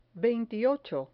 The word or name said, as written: veintiocho